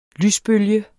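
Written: lysbølger

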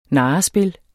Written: narrespil

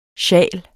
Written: SJALERNE

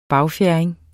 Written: bagfjerding

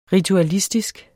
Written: ritualistisk

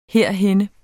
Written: herhenne

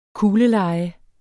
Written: kugleleje

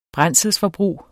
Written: brændselsforbrug

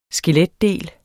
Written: skeletdele

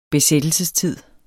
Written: besættelsestid

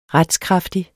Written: RETSKRAFTIG

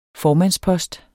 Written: formandspost

